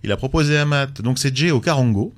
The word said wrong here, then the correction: charango: et là au charango. – charango au charango, excuse-moi.